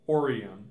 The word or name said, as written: Orion